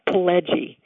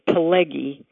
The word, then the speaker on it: Pileggi: Hi, my name is Leah Pileggi. Sometimes people pronounce it Pileggi, but the double G in Italian is a soft G sound.